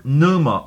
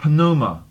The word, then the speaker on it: pneuma: The word Spirit in the Greek text from which your translation was translated is the word pneuma or pneuma. It's spelled P-N-E-U-M-A. And you can pronounce it either with the P or without it. Pneuma or pneuma.